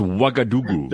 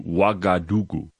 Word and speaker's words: Ouagadougou: It's Ouagadougou. – Never mind. Oh, Ouagadougou. – Yeah, and you spell it O-U. It starts with an O-U. Ouagadougou.